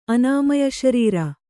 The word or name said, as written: ಅನಾಮಯಶರೀರ